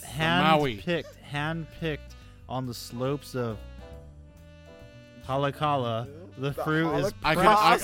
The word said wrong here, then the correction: Haleakala: I can pronounce it because I've been there before. It is actually pronounced Haleakala.